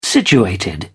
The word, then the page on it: situated: British English